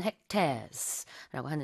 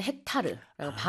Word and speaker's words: hectare: 영어로는 hectares라고 하는데요.